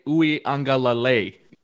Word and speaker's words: Uiagalelei: But yeah, it's DJ Uiagalelei.